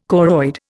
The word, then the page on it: choroid: Indian